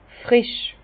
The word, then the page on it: frisch: Bas Rhin; Herrlisheim